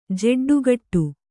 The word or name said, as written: ಜೆಡ್ಡುಗಟ್ಟು